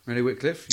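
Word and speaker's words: Renee Wickliffe: Yeah, I've gone for two New Zealanders, so I've backed her up with Portia Woodman and then Renee Wycliffe. Probably pronouncing that wrong. – No, Renee Wycliffe, yeah.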